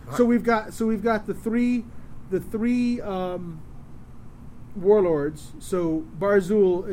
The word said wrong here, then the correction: Bar-Arzul: is a warrior from the Korn. I'm sorry, it's Bar-Arzul.